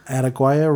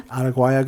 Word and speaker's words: Araguaia: Araguaia River. Based in the Amazon. – Yeah. – From 1967 to 1974, the so-called, how do you say it again? – Araguaia. – Araguaia guerrillas tried to establish a rural stronghold in order to topple Brazil's military dictatorship, recruiting farmers and fishermen in their cause.